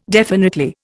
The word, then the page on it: definitely: Indian